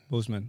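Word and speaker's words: Boseman: Bossman? Am I saying that correctly? – Bozeman. – Okay.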